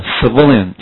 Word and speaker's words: civilian: 最后加上 on 变成人嘛，所以重音不再念成 civil，而变成 v 的前面叫做 civilian，清楚了吧？